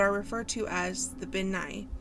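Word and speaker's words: bean nighe: So it's spelled B-E-A-N, like bean, but it's Ben. – B-E-A-N. – Ben. And then Nye is N-I-G-H-E.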